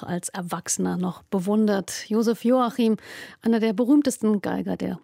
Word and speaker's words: Joachim: Und weil er in Ungarn geboren wurde, betont man ihn auf der ersten Silbe Joachim.